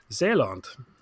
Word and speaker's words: Zeeland: Ceylon sounds a lot like the Dutch pronunciation of their southernmost province, Zeeland.